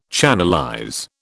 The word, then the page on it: channelize: British English